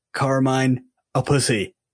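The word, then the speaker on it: Carmine Appice: Now, I looked up how to pronounce Carmine a Pussy, and that's how you say it.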